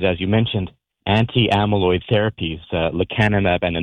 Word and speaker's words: lecanemab: I'm probably going to mess up the pronunciation, but the drug is lecanemab? – Lecanemab, yeah.